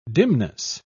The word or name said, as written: dimness